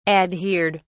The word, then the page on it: adhered: British English